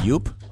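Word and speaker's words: Joop: I was told it's pronounced Yope. – I thought it was Jew, and the P is silent. Isn't that right, Bob? – You remember you and I Gibson? Went to the Jew trunk sale, didn't we? – Jupe. – Wasn't me. – Jupe. – With a P. – Yope. – But it's pronounced Yoop. – That's what I was told when it was given to me, like 1989.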